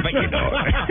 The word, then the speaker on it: Feyenoord: Eso, Feyenoord.